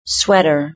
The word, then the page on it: sweater: British English